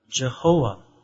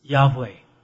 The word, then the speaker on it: Yahweh: Jehovah. Or Yahweh. But most likely, the proper way to pronounce is Yahweh. So Yahweh, that will be most proper name.